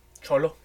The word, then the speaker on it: Xolo: Eh, se muestran las primeras imágenes de Sholo Maridueña, creo que así se llama. Sholo, Sholish. Eh, su nombre se escribe X-O-L-O. Solo Solo Maridueña.